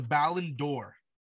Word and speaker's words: Ballon d'Or: The Ballon d'Or, did I pronounce that right? – Yeah, let's go for it.